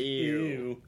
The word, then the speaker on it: ew: I like how you say ooh. – Why? – You're like, I say ew. Ew. – How do you say it? – Ooh. – Ooh. – Ew. – You're such a man.